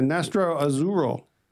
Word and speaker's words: Nastro Azzurro: Today, we're going to go with the Peroni Nastoro. How do you say? Nastro Azzurro, which is Italian for yogurt.